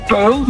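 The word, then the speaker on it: Puij: Het is gewoon Puil, maar het wordt dus U lange ei wordt het geschreven.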